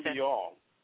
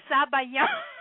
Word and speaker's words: sabayon: Oh, sabayon. – There you go. – Sabayon. Sabayon is actually French word, and in Italian it's zabaglione, which is basically the same thing.